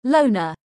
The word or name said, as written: loner